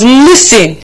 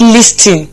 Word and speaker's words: listen: And I thought you are actually going to be learning how to make, how to pronounce the word L-H-I-S-T-E-H-E-N. L-H-I-S-T-E-H-E-N. And the word is pronounced listen. Listen, not listing. It's pronounced listen. I repeat, listen. I repeat, listen. Listen and not listing. So it is pronounced listen. The T is silent.